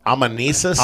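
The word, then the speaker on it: anamnesis: The amen. I'm going to say it wrong here. – Um, am, amenesis. – Amenesis. – There we go.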